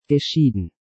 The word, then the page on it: geschieden: German